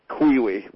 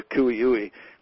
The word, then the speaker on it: cui-ui: which was the Lahontan cutthroat trout and the Kuiui, which is spelled C-U-I-U-I, or the Kuiui, which is an endangered species, and then the Tui chub, which is the endemic bait fish for the Great Basin.